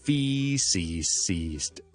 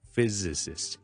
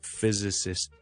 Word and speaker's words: physicist: physicist 就唔好读成 physicist 就唔得，系 physicist。第一个 s 咧系 z 嚟嘅，所以系比较难读啲嘅。听到先，physicist。